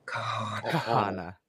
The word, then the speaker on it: Kahanna: This is like, if you're going to say her name, you got to say it right. It's Kahana.